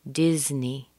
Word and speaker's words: Disney: Be careful with the short I in Disney. That's not a long E. So make sure that your face and cheeks are relaxed. You don't want to say Disney. It's Disney. Disney.